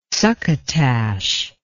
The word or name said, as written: succotash